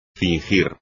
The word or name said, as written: fingir